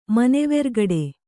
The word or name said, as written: ಮನೆವೆರ್ಗಡೆ